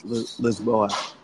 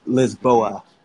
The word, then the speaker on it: Lisboa: That's how you say it, right? Lisbon? – Lisbon. – Lisbon. – Or for the locals, it's Lisboa. – Lisbon. – Lisboa. – Lisboa.